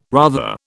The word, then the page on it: rather: British English